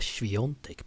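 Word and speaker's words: Świątek: Tror att hon har polsk påbrå eftersom att en av hennes favoritspelare på touren, det är Iga Swiatek Patrik. Ja, bra uttal där.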